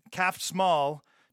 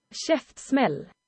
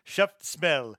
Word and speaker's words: Käftsmäll: Calf Small translates to Jaws, or in Swedish, it is Käftsmäll. – Käftsmäll, so I'm saying it wrong. I'm looking at it and saying it as if I would say an English word.